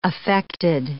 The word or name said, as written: affected